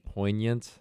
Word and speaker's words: poignant: I mean, I think you were in the room whenever I was looking into the word poignant. – Oh, he got it. First try. – Yeah, I've been working on that one. – Sounded good. Good pronunciation. – Can you spell poignant? – P-O-I-G-N-A-N-T.